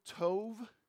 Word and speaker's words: tov: It's a, it's a little word in the Hebrew, tov, rhymes with stove.